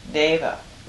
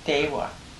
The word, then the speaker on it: deva: They both come from the Sanskrit word and the Pali word deva. – Right. – Dewa. – Yeah. – Tibetans pronounce it dewa.